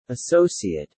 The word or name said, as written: associate